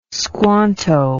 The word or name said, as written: squanto